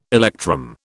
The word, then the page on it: electrum: British English